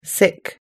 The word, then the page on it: sick: American English